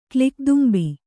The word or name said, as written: ಕ್ಲಿಕ್‍ದುಂಬಿ